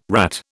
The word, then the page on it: rat: British English